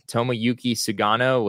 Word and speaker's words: Tomoyuki Sugano: The name that he mentioned, Tomoyuki Sugano, which, yeah, I'm probably butchering that worse than you did.